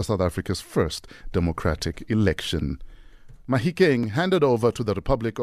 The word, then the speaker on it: Mahikeng: The handing over also affected the name change of this town from the British pronunciation of Mafeking to the Setswana name Mahikeng, meaning the place of stones, or boulders, if you will.